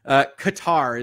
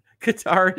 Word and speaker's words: Qatar: Is it Qatar or is it Qatar? Help me out. I will say it however y'all like. Qatar is what they want. Okay. Look, I'll pronounce it however you like, folks. If that is correct, if you're telling me that, I'm more than happy to do it. Qatar is correct.